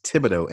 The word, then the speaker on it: Thibodaux: But the city I live in is Thibodaux, and it's spelled T-H-I-B-O-D-A-U-S.